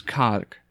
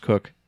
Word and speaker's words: Koch: It's like pronouncing somebody's last name as K-O-C-H. It's Cock. But, you know, it's actually pronounced Cook.